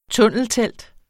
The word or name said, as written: tunneltelt